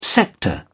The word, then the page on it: sector: British English